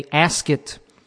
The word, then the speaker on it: Asket: It's called it's a Asket rhymes with basket brand T-shirt.